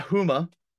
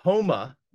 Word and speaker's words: Houma: I just want to state for the record, it's not Houma, it's Houma, Louisiana.